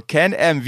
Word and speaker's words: Can-Am: So, also, Can-Am, wie, genau wie ich es richtig gesagt habe.